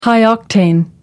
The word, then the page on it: high octane: American English